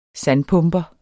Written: sandpumper

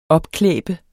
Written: opklæbe

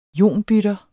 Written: IONBYTTER

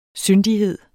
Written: syndighed

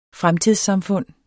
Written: fremtidssamfund

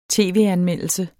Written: tv-anmeldelse

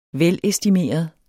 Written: velestimeret

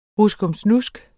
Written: ruskomsnusk

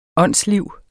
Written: åndsliv

